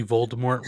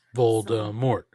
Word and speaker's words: Voldemort: Voldemort. Voldemort. – Well, if there's a British person listening, which I would doubt, but if there is, they're so mad right now that we're pronouncing the T.